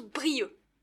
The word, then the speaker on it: Brieuc: Avec un C à la fin. On écrit Brieuc, mais on prononce Brieux.